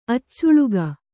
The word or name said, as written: ಅಚ್ಚಾಳಿಕೆ